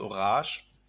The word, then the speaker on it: Orange: Ja, der heißt Orange. – Also Französisch. – Also es wird Französisch ausgesprochen. Es ist nicht Orange und falsch geschrieben.